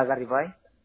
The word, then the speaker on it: Garibay: Garibay. – ¿Cómo se escribe? – G-A-R-I-B-A-Y.